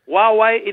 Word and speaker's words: Huawei: So Huawei it is, and it's a brand new P30 and P30 Pro.